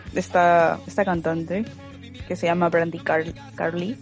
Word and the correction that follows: Carlile: Brandy Carlile, eh, que canta folk o slash americana.